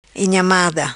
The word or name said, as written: Inhamada